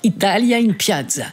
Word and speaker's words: Italia in Piazza: Est-ce que je prononce bien? Parce que Denis se demandait si mon accent était bien tout à l'heure. Italia in Piazza, c'est bien? – Il est parfait.